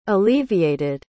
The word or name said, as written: alleviated